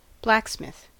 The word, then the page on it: blacksmith: American English